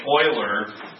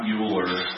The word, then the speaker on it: Euler: By the way, it is pronounced Euler, not Euler.